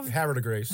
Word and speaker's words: Havre de Grace: Havre de Grace. – How you say it? I was going to be something French and sound more floral.